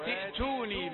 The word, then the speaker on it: ائتوني: لا تقل ائتوني لا تقل إذا بدأت ستنطق همزة الوصل فتقابلك همزة خطا لا